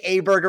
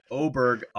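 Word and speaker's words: Åberg: I think we're saying that correctly now. I would still like to say Ober, but I also wanted to say Aberg originally. We'll settle on Oberg. I'm not changing that name again.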